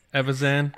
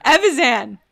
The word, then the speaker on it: Evazan: Evazan. – Evazan.